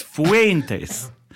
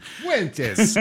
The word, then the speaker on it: Fuentes: The first syllable's got to rhyme with hey. It's Fuentes. – Fuentes, yeah, okay.